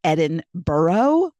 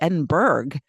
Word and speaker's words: Edinburgh: I, I can give you a little trick on how I found out how that actually is pronounced, uh, and I always thought it was Edinburgh. That because and then I looked at when we were going, it was like no, it's Edinburgh. So then I started saying Edinburgh.